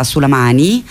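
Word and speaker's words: Sulaimani: e poi sono stata a Sulamani, che noi in italiano spesso pronunciamo Sulamania, in realtà loro appunto dicono Sulamani,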